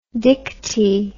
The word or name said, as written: dictee